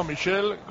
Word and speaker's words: Michel: No, Michel, como Platini, Michel Platini.